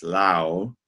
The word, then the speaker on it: Laos: People often pronounce it Laos, but it's Laos.